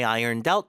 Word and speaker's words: ironed out: I ironed out my dress for the reception. Ironed out. ED가 붙었으니까 D가 붙어서 이번엔 doubt 이렇게 발음이 되겠죠. 뒤에 힘 주고